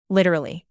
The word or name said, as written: literally